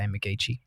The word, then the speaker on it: McGeechee: So I just kind of keep it quiet and just keep it out of there, not out of my mouth in the incorrect way, but hopefully Dad's not listening. But I just say McGeechee. Spells McGeechee, yeah? – Okay, all good.